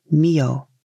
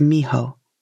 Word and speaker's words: Miho: The author called the area where this legend happened Mio. It might have gone by that name back in the day, but these days it's pronounced Miho, so I'll read it with the current pronunciation.